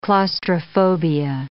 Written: claustrophobia

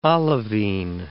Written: olivine

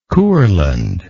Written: Courland

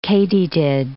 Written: katydid

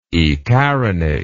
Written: ecarinate

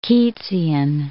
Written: Keatsian

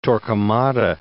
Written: Torquemada

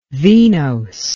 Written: venose

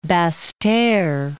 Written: Basseterre

